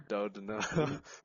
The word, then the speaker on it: Doudna: Doudna.